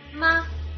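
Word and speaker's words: ma (吗): Il primo si ottiene partendo dalla forma affermativa della frase e aggiungendo alla fine una parolina che si chiama la particella interrogativa e si pronuncia ma.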